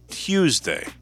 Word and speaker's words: Tuesday: You call it Tuesday. Tuesday. You pronounce the U.